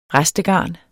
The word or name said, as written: restegarn